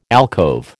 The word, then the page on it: alcove: American English